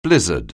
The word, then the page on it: blizzard: anglais